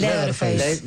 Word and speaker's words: Leatherface: El Leatherface.